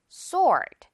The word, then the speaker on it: sword: 자, 그런데 이 W 오늘 유령 친구입니다. 그래서 sword, 우리 W 그냥 아예 빼고 생각하면 돼요. S-O-R-D라고 생각하면서 발음을 해야 돼요. sword.